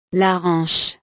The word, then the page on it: la hanche: French